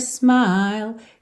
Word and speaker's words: smile: I can see it in your smile. Atenção que a vogal que é longa é esse I aí do meio, smile. E aí o L só encerra a palavra porque o E final não é pronunciado.